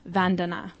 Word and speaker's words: Vandana: I think it might actually be pronounced Vandana.